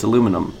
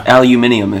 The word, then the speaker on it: aluminium: It's like the shape of a typical glass bottle, but it's aluminum. – Yeah. – Aluminium, if you will.